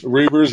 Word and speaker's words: Reuvers: And you have Potter and Reuvers, or however you say his name. – Reuvers, yeah. Reuvers, yes.